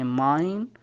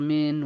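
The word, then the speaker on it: mine: هذا بالخطوة الأولى أن الآي ينطق بحرف الآي إذا أتى الحرف الثاني اللي هو حرف الإي، مثل الكلمة الأولى سمايل، الكلمة الثانية ماين، الكلمة الأخيرة واير. ما ينطق وير ولا ينطق مين ولا ينطق سميل.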